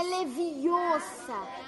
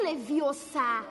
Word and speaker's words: Leviosa: E você está falando errado. É leviosa e não leviosar.